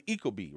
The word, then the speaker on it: Ecobee: But now that's called the Ecobee, right? – Am I saying that right? That is exactly right.